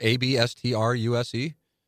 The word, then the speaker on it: abstruse: Obstruse. Is that how it's pronounced? Abstruse? – Yep, that's right.